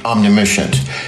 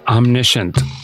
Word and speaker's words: omniscient: I think omniscient. – It's pronounced omniscient, Einstein.